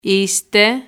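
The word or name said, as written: είστε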